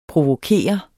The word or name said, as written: provokere